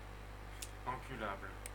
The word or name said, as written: enculable